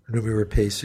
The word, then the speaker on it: Noomi Rapace: Noomi Rapace. Is that how you say her name? – Noomi Rapace, yeah.